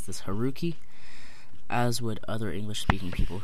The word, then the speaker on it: Haruki: But it's pronounced Haruki. And the U in Japanese is pronounced very interestingly. It's not u, like tu in Spanish. It's u, like tsunami.